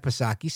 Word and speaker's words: Psaki: But Jen Pacircleback Psaki, silent P, she thinks that it's absolutely necessary.